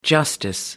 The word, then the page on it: justice: American English